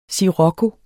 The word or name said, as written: SCIROCCO